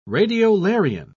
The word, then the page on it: radiolarian: British English